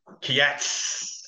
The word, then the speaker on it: Kiatz: Just on the pronunciation, the owners like to pronounce this horse's name, Kiatz.